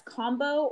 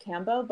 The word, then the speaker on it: kambo: It's it's Kombu, or some people say it Kambo, but I've watched a few documentaries of Indigenous people and they pronounce it Kombu.